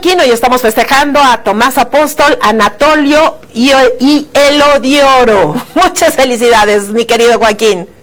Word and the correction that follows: Eliodoro: Eliodoro, sí lo dije mal, Eliodoro.